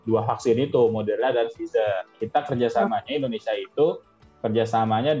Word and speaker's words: Pfizer: Pfizer. Ya gitulah ya. – Pfizer.